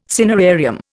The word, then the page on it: cinerarium: Indian